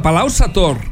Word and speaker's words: Palau-Sator: Perquè ahir la va cagar amb el tema de Palau-Sator. – Com ha anat l'enquesta a Instagram? – 88% han dit que va ser acabat amb R i la R es pronuncia.